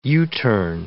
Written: U-turn

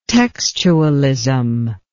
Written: textualism

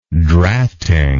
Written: drafting

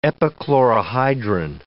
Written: epichlorohydrin

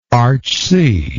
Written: archsee

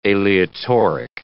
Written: aleatoric

